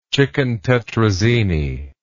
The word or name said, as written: chicken tetrazzini